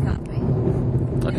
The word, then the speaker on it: Capri: What is the real pronunciation of that island? – Italian is Capri. – Okay, well, you're Italian. It's Capri.